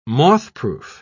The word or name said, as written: mothproof